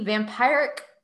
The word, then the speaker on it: vampiric: originosa, but also to disrupt its protective shield makes this sneaky vampiric? Is that a word? – Vampiric. – Oh, is that how you pronounce that? – Yes.